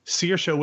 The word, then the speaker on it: Saoirse: And for her monologue, to kind of help people understand how to actually pronounce her name, that's why I thought it was funny that you butchered it, she did her own rendition of Liza with a Z. And while Liza with a Z is actually pretty helpful if you pay attention, Saoirse with a sheer, not as helpful, but it's really funny.